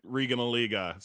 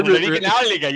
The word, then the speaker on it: Regionalliga: This is a Devin special because he called his national championship at Marshall, and Devin also loitered in the German Regionalliga. So, like, this is Devin in a nutshell. – Oh, we got to work on that pronunciation. Das ist Hessenkessel, Regionalliga, yeah?